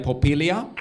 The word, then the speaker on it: Porpiglia: And the wonderful Santina Popilia.